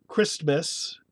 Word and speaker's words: Christmas: Christmas, S is the fricative, F, S. And then M is a sonorant, M is, and then the T is silent.